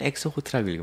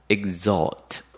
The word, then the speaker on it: exhort: 그러면 exhort에서 나왔는데 읽을 땐 exhort라고 읽으면 안 되겠죠. exhort 이렇게 읽고요.